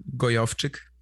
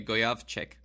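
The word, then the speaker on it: Gojowczyk: Gojovcik. – Okay, Gojovcik.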